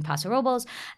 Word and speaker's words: Paso Robles: We have our friends in California, Semolina Artisanal Pasta, and Eto in Paso Robles. And then that's how you say that? – Oh, yeah, you haven't been to the Central. – I didn't know that. Paso Robles? – No.